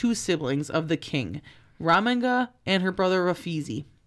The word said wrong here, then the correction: Rafazi: And, or Rafazi, sorry. I think it's a long A.